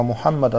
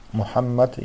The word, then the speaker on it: Мухаммад: Из-за этого человек должен произносить Мухаммад именно как арабы произносят, то есть удваивает букву М, буква Х, она мягкая, то есть Мухаммад так говорит.